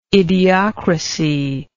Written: idiocrasy

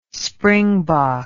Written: springbok

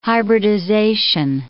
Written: hybridization

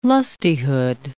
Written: lustihood

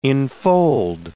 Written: infold

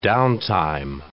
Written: downtime

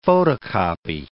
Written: photocopy

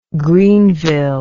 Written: Greeneville

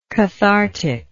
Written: catharticalness

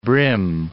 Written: brim

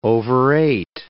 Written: overate

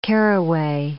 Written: caraway

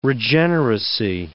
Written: regeneracy

